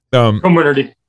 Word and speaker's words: Comirnaty: And then they wanted to get full approval under Comirnaty. I might be saying it wrong. – Yep. Comirnaty.